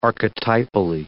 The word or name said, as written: archetypally